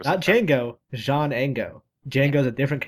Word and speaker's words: Django: Yeah, I figured that was a joke, but then I realized there was another character named Django. – Well, this one is with a D. The D is silent.